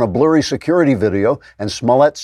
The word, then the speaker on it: Smollett: then demanded Smollett put the accent of his name on the first syllable like a normal person and stop trying to be such a fancy pants.